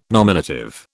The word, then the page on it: nominative: British English